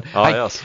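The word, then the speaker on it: Aarhus: What's the pronunciation of that club again? – our ah, ah, house, Not too ah, sure. well done. Arhaus.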